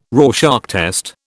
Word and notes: Rorschach test: British English

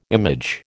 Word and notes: image: American English

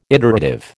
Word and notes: iterative: American English

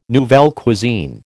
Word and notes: nouvelle cuisine: American English

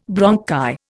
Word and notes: bronchi: Indian

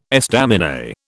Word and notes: estaminet: British English

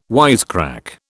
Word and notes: wisecrack: British English

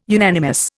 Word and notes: unanimous: Indian

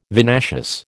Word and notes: vinaceous: American English

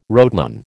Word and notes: rotenone: American English

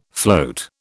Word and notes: float: British English

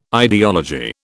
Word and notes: ideology: British English

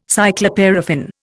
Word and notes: cycloparaffin: Indian

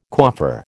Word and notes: coiffeur: American English

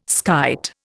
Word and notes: skite: Indian

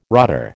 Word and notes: rotter: American English

